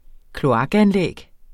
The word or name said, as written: kloakanlæg